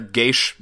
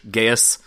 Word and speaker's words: geas: It's pronounced a million different ways, but G-E-A-S, the Gaish, Gaius, yeah. – Gaias, that mm-hmm.